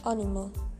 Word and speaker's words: animal: Luego, nosotros pronunciamos animal y los ingleses en cambio animal.